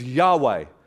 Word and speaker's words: Yahweh: We pronounce it as Yahweh, which is one of the names of God, although the people of Israel would never pronounce that.